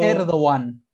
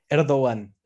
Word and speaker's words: Erdoğan: Erdogan. Erdogan, así me dijo. Recepta Tayyip Erdogan. Y él lo pronuncia así, Erdogan.